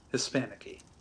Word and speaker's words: Hispanicky: And it's spelled at Hispanicky, like the name Nicky. Hispanicky.